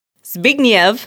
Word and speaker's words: Zbigniew: Yeah, that's how you say it. Zbigniew Zamachowski. Which, thank you to Maya, because she's the one who told us how to pronounce his name.